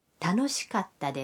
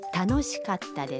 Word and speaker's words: 楽しかった: 的过去形是楽しかったです。 – 下面请大家跟着录音来练习一下发音。请注意卡达的发音，要在卡和他中间停顿一拍。 – 楽しかったです。